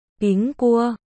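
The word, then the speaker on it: Píng Cua (苹果): Trong tiếng Trung, quả táo được phát âm là Píng Cua, đồng âm với chữ bình trong từ đêm bình an.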